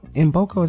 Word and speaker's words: Mboko: Mboko is how you say it. Victoria Mboko is M. It's K-M-B-O-K-O.